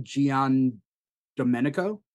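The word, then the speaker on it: Giandomenico: Gian Domenico.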